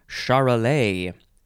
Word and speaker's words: Charolais: It is pronounced Charolais. It is spelled capital C-H-A-R-O-L-A-I-S, Charolais.